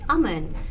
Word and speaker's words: Aman: I'm like, it's Aman.